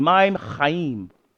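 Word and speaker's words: mayim chayim: Okay, Maim, repeat after me, Maim Chaim. You have to roll your R as if you're about to spit to the person next to you. Maim Chaim. Thank you very much.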